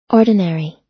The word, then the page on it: ordinary: American English